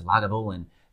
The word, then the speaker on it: Lagavulin: And you even know how to pronounce it. Lagavulin.